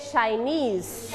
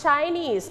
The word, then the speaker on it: Chinese: Chinese. Not Chinese, but Chinese. Very good. We stayed on the second syllable.